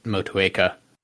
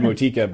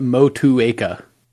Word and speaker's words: Motueka: And I looked up how to pronounce Motueka, so I hope I'm pronouncing that right now. – Yeah, we were saying more like Motika before. – Yeah. It seems like a four-syllable word from the very quick research that I did.